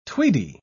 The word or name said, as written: tweedy